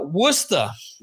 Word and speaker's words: Worcester: There's a place up in Massachusetts that's close to him that is uh, Worcester. Worcester, yeah, Worcester. Yeah, but it's spelled Worcester.